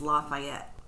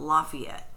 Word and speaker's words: Lafayette: I also want to point out, around the world, most people pronounce the name of this city as Lafayette. But in Indiana, we call it Lafayette. – Mm-hmm. – My grandfather even butchered it worse, and he said Lafayette. So I'm not mispronouncing it when I say Lafayette. It's just Indiana speak.